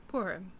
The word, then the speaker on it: Purim: Purim. – Purim. – Purim. – Okay. – Purim. – Purim.